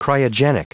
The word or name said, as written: cryogenic